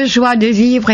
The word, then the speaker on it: joie de vivre: Michel, tu es pleine de joie de vivre et j'adore vraiment t'entendre rire.